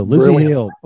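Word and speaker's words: Lzzy Hale: Lizzie Hale, and her first name is spelled L-Z-Z-Y. See how she did that? – Right. Yeah, without the vowel in there. So Lizzie Hale.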